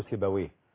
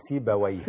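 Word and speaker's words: سيبويه: وبالمناسبه الناس بتنطقه خطا، يا بيقولوا سيبوي. هو سيبوي.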